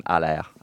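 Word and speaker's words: Haller: It is in fact Sebastian Allaire and not Sebastian Ali.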